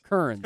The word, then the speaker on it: Kearns: So it is like a extra syllable, but you almost don't say it like it does. – It's just like Colonel, but take out the Kearns. – It's just Kearns. – Because I was thinking like Kern with an S, but with a K.